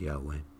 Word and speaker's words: Yahweh: It's also known as Jehovah, but it's more accurately pronounced Yahweh.